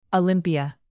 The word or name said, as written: olympia